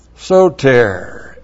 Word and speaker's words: Soter: Soter, S long O T long E R.